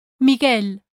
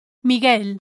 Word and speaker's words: Miguel: Number one, the letter U is silent in the cluster G-U-E, ge. For example, in previous lessons we saw the words l'hamburguesa, the hamburger, l'hamburguesa, and Miguel, Michael, Miguel. Rule number two says, the letter U is silent in the cluster G-U-I, gi.